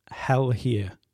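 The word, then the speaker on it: Helghyer: Next up is an indie folk artist from Berkshire in the UK, performing under the name Hell Here. I think that's how it's pronounced, after doing a bit of digging around.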